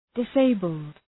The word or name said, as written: disabled